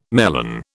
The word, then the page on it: melon: British English